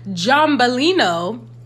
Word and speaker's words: Giambellino: Did I forget how to pronounce this? Giambellino from Aldi. $8.99. Okay, make sure you know you say it like that.